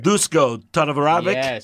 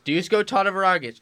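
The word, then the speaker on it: Duško Todorović: Dusko Todorovic. – Yes. Dusko Todorovic.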